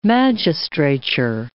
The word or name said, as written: magistrature